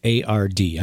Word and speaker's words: Maillard: Maillard.